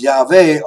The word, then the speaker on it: Yahweh: And in Hebrew, the W is pronounced like a V, as in Yahweh. So phonetically, the name should be pronounced Yahweh, although the anglicized Yahweh has become very common among CI people.